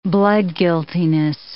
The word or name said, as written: bloodguiltiness